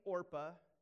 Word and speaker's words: Orpah: One named Orpah. Yes, that looks a lot like Oprah, and Oprah's real name is actually Orpah, but people didn't know how to pronounce it, so they call her Oprah.